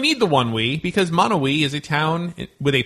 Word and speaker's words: Monowi: Oh, it's spelled M-O-N-O-W-I, okay. – Monowi.